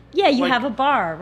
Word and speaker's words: barre: Because it's spelled B-A-R-R-E? – Yes.